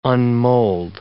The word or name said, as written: unmold